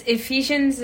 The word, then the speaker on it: Ephesians: It's Ephesians 4:2. Did I say that right? – Yeah.